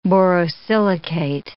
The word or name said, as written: borosilicate